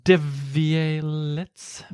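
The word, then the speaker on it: Devialet: men företaget bakom är ett franskt företag som heter Devielier eller någonting. All right. du, får, du får säkert uttala det där bättre än vad jag kan. Devialier. – Devialets. Devialets. – Devialets. Ja. Jag läste tyska.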